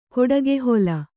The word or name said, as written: ಕೊಡಗೆಹೊಲ